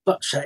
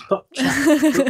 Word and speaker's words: Boccia: So you mentioned Boccia, and is that's the right way to pronounce it, isn't it? – Boccia, yes. Yeah. Well done. A lot of people say it wrong, and I'm like, no, it's Boccia.